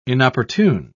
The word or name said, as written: inopportune